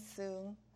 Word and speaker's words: Sue: Mattie Sue Wright.